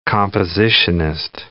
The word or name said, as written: compositionist